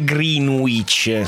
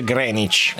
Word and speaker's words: Greenwich: Che tra l'altro non si dice neanche Greenwich, si dice Greenwich. – Lo so. – Però perché non dici Greenwich? – Ma perché tutti dicono Greenwich e se dico Greenwich sembra che sbaglio e tutti pensano di me.